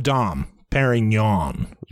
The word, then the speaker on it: Dom Perignon: Now, many people pointed out that this was a bottle of Dom Perignon.